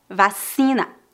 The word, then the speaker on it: vacina: Lembre-se que a letra C é pronunciada como S diante das vogais E e I. Vacina.